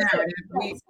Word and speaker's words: K-Narrative: The K is silent. So it's just narrative.